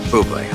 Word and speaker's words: Bublé: She's right, Michael Bublé. It's pronounced Bublé.